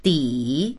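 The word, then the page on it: di3: Mandarin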